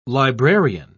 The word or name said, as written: librarian